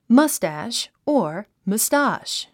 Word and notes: mustache: mʌ́stæʃ; マスタァシュ

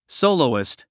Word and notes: soloist: sóulouist; ソォロゥイスト

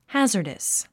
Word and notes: hazardous: hǽzə r dəs; ハザァダス

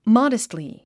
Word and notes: modestly: mάdəstli; マデストリィ

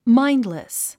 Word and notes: mindless: máindlis; マインドリス

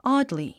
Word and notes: oddly: άdli; オォドリィ

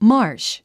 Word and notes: marsh: mάːrʃ; マァッシ

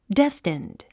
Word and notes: destined: déstind; デェステンド

